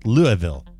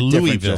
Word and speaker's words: Louisville: That's incorrect. It's Louisville. – Louisville. – You said, let me hear it again. – Louisville. – Louisville is correct.